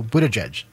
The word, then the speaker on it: Buttigieg: So Buttigieg, right? – Yeah, no, that's right.